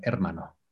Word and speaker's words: hermano: Remember the H is silent, so it's er, hermano.